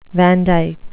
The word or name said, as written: vandyke